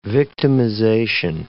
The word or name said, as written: victimization